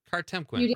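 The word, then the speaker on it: Kartemquin: Did I say that right? Cartemquin, right? Yeah, yeah, I thought so.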